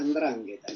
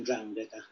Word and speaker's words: 'ndrangheta: Y 'ndrangheta, los dialectos del sur de Italia y de Calabria se pronuncian 'ndrangheta, algo por el estilo, pero no importa demasiado, digamos, la nominación o como suena.